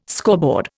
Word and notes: scoreboard: Indian

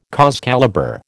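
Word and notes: cause célèbre: American English

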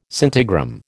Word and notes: scintigram: American English

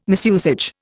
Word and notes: misusage: Indian

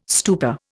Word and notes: stupa: Indian

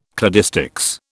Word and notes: cladistics: British English

